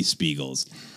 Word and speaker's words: Speagull: S-P-E-A-G-U-L-L. – Oh, that's why. These I did not spell it. It's like seagull. – Like basically, like seagull. Seagull with some extra.